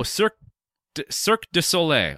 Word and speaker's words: Cirque du Soleil: No, they're, James Cameron's in talks with Cirque Soleil to do an Avatar version of Cirque Soleil. Is that how I pronounce it? – Cirque du Soleil? – Oh, Cirque du Soleil.